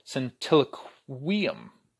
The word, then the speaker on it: centiloquium: which is actually called a centiloquium. That's how you pronounce it, which means 100 sayings.